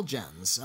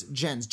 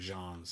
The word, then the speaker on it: Gens: Most people have a hard time knowing how to say it, so it's gens. G E N S is pronounced like Gen X or gens.